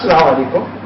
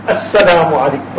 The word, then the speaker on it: As-Salaamu Alaikum: It's not Salaam Alaikum, As-Salaamu Alaikum. Pronounce that Alif, that Hamza at the beginning.